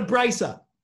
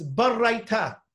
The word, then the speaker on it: baraita: So the barraita, and it's not a braisa. It's barraita. That's how you're supposed to pronounce it.